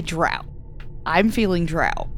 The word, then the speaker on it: drow: I say drow as well because that's how I've pronounced it since I started reading some certain series of books dozens of years ago. – It rhymes with cow.